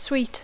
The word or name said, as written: sweet